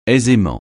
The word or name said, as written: aisément